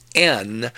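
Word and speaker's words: en (ἐν): When he says, in the Lord, he is careful to use the preposition in, which in Greek is pronounced en, which quite definitely draws a circle around the word it modifies.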